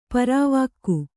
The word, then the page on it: ಪಾರ್ವಿಕೆ: kannada